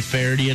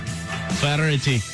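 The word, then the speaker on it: Feherty: Yeah, they might just be waiting because they had the big David Faraday announcement last week. – You didn't say it right. – Faraday. – David Faraday.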